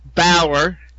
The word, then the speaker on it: Bauer: Here I go with the name. Bauer. Mm-hmm. I think I said it right this time.